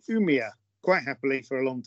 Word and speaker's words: Umeå: It sounded pretty good. You maybe should try to learn from more Swedish. maybe the correct. It was pretty good. In Sweden, we say Umeå. – Umeå. – Umeå.